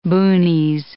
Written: boonies

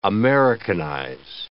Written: Americanize

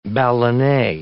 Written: ballonet

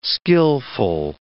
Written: skillful

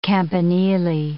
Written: campanili

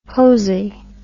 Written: posey

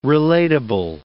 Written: relatable

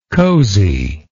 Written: coziness

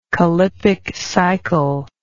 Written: callippiccycle

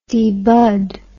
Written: debud

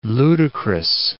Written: ludicrous

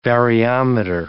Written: variometer

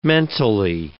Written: mentally

